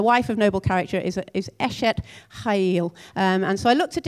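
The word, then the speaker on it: Eshet Chayil (אשת חיל): So the phrase we read as the wife of noble character is, in Hebrew, I guess, is pronounced Eshet Chayil, except you've got to do the CH as a ch. Eshet Chayil. Give it a go. Eshet Chayil.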